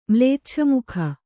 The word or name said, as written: ಮ್ಲೇಚ್ಛಮುಖ